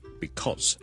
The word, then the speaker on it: because: because 呢個字個發音，好多時候咧，我哋係唔會讀成好完整嘅，我哋正確啦，becaus，我哋 because 係 be 啦，第一個 vowel 係 a 啦，第二個 vowel 係 o，跟住後面係一個 z 音，because，但係咧喺口語嘅時候咧，尤其是當呢個字唔係咁緊要嘅時候咧，我哋可能會讀得好快好快，大家都可能會見過 coz 呢個簡寫，OK。